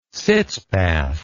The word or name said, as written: sitz bath